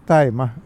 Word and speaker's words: tajma: Så det, vi var inne på den vältajmade babyn där och tajma skriver man då T-A-J-M-A därför att skriver man tima så vet man inte hur man ska uttala det på svenska.